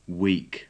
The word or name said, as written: week